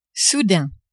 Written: soudain

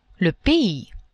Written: pays

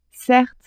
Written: certes

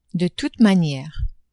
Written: de toute maniere

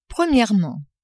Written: premierement

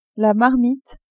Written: marmite

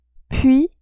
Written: puis

